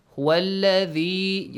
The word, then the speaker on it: الذي: The pronunciation of the letter ذال is important, okay? How to pronounce it correctly, it's important. It shouldn't be mixed with the letter ز. هو الذي. You bite your tongue a bit.